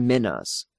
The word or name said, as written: minas